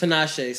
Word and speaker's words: Tinashe: Name 5 Tina Shay songs. – Tinashe songs.